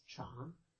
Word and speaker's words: Chan: Essentially, Zen is the Japanese pronunciation of the same character that in Mandarin is Chan.